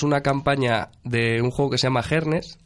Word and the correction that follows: Jerjes: Jerjes. – Jerjes, bueno, yo lo pronuncio mal. Yo lo pronuncio este mal.